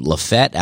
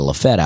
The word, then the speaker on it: Lafayette: Uh, I was born in Opelika, and then I moved to Penton, Alabama, which is right outside of Lafette, Alabama uh, which is spelled Lafayette. And um, I don't even know if I'm pronouncing it the right way. I, you know, I always say that's how people in the town pronounce it, but I think that may be my own. They may say Lafayette, but I say Lafette.